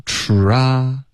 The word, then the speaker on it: try: 可是这两个音都没有嘟嘴，OK，需要嘟嘴吃，然后再张开嘴巴 try，然后再滑到一个 e try，所以跟中文的一些发音，比如菜菜。的这些发音是不一样的，所以这一定要练好，因为它在口语中是一个高频词。 OK，要练这个嘟嘴的 try。